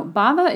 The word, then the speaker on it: Bhava: It is a Sanskrit word that is, and again, I'll spell it for you, B-H-A-V-A. So that H is kind of silent.